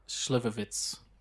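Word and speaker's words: Slivovitz: It's not a ch sound at the end. It's a TS. – Schlivovitz.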